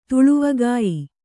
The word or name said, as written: ತುಳುವಗಾಯಿ